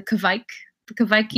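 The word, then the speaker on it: Kveik: Like, say, for example, the Kvike yeast. – You're saying it right. – Good. – The right way to say it.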